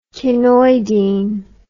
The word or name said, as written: chinoidine